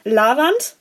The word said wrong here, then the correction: leiwand: richtig oder falsch? – Leiwand, meinst? – Ja. – Das ist Leiwand.